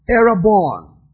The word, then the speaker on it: erabon: The word earnest is from the Hebrew word erabon. E-R-A-B-O-N. Erabon. It has only one rendition that is useful. One rendition. Genesis 38. Let's look at it together. Genesis 38. Erabon.